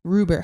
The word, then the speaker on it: Ruber: It's a Ruber. How do you say his name? – It's Ruler. Sir Ruler. – Ruber. How do you spell it? R-U-B-E-R? – Yes.